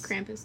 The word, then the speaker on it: Krampus: How do you say Kramp, Krampus? Krampus? Krampus.